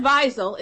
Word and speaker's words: Wiesel: It's not Wiesel. It has to be Wiesel because it's I-E, and that's, you put the emphasis on the, okay. – on the E. Yeah, so when people say Wiesel, they're really going off wrong.